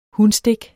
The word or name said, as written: hunstik